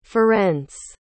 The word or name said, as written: ferenc